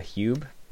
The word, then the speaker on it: Huub: So I was able to get a hold of one of the Hube, it's spelled H-U-U-B, wetsuits.